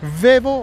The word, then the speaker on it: Vevo: Pero sí, sí, lo de Vevo o Vivo, como quiera que lo pronuncies, está con YouTube.